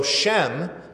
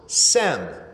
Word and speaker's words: Shem: In Hebrew, the letter can be both an S sound and an SH sound. And so Shem can also be pronounced Sem.